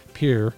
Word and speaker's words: Pierre: Yeah, that's how you pronounce it there, Pierre.